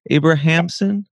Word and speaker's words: Abrahamsson: Um, and uh, to kind of segue into relationships, uh, your husband, Carl Abrahamsson, Abrahamsson, is that how you pronounce it? Yeah. Who I've also been following, and your guys' work together.